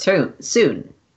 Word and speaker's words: Sune: That's how her name's pronounced. Sune.